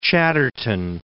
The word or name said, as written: Chatterton